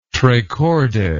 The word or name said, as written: tre corde